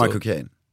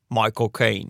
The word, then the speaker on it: Michael Caine: Det är som att säga My Cocaine. Alltså, Michael Caine. – Michael Caine. – Ja, för han pratar ju Cockney.